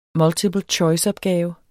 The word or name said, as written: multiple choice-opgave